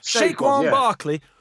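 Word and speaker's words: Saquon Barkley: Shaquan Barkley.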